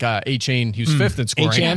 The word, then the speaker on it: Achane: He said Achane because that's the pronunciation guy. That was the clip on McAfee, and then Achane corrected, and then... It was like Achane told Peter King on Sunday night that it's Achane.